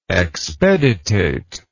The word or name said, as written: expeditate